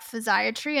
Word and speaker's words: physiatry: If you have MS, look up physiatry, and no, I'm not mispronouncing psychiatry.